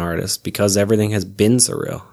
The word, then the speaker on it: SRIL: It's pronounced surreal, but it's just S R I L.